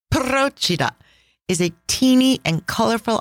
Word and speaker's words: Procida: Let's start with Procida. That is spelled P-R-O-C-I-D-A, and the emphasis is on the first syllable, just like Capri.